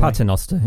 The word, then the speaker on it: Paternoster: Paternoster. Is that how you say it? Well, it's brilliant anyway. – Paternoster, yeah.